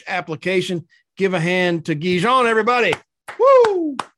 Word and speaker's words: Guy Jean: In America, we'd say Guy Jean, but en français, we pronounce it Guijon. So I'm going with Guijon.